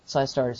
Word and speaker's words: Psystar: Like, SciStar, it's spelled PSYSTAR, and it's just advertised right there.